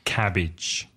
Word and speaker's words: cabbage: In English, cabbage. C-A-B-B-A-G-E. Ojo, be careful of the pronunciation. Idge. Idge. Cabbage.